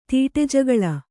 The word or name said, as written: ತೀಟೆಜಗಳ